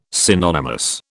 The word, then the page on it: synonymous: British English